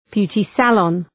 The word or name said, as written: beauty salon